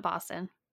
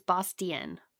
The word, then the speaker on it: Bostian: It's not Boston. It's Bastion. Bastion. B-O-S-T-I-A-N.